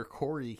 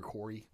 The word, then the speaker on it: Khoury: They treat this Peter Corey case, and that's like K-H. Let me spell it real quick. K-H-O-U-R-Y. – Mm-hmm. – Peter Corey.